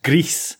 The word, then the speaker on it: Gris: It's pronounced Greece, because it is Spanish for the color gray.